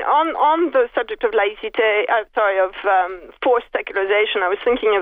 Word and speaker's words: laïcité: And, you know, we've been hearing a lot about the French philosophy of laïcité.